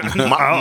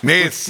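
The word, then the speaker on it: Més: Mass. – Mas. – Mes. Mas. – Mes. Katalanisch. Wird anders ausgesprochen, Katalanisch, natürlich.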